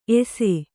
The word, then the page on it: ಏಸೆ: Kannada